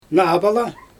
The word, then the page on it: naabala: Xyzyl